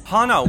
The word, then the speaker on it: Hannah: Hannah with sort of a soft A. – Exactly, exactly. Hannah like honey.